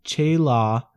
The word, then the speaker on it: challah: Am I pronouncing that right? – Yeah. It's pronounced Challah bread.